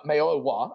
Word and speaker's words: Mayowa: And they went out and signed Benson Mayoya. Yes. Excuse, uh, Mayowa. I got to get that pronunciation. You got right.